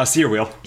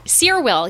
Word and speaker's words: Cyr Wheel: Because I see you on Instagram all the time with, am I saying it right? The Cyr Wheel? – Uh, Cyr Wheel. – Cyr Wheel, yeah.